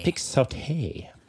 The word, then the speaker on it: Pixote: Pixote.